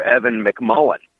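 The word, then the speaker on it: Evan McMullin: They could go for Evan McMullen.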